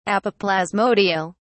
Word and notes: apoplasmodial: British English

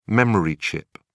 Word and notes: memory chip: British English

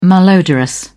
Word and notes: malodorous: British English

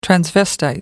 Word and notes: transvestite: American English

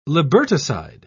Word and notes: liberticide: British English

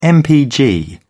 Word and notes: mpg: British English

